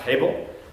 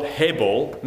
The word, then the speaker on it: hebel: I'm going to say it like that, hebel.